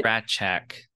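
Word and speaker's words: Rasczak: I don't know if I'm pronouncing that right. – Ratchak. – Ratchak, okay.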